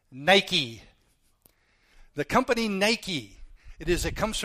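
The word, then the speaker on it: Nike (νίκη): In the Greek, it's pronounced a little different. It's Nike.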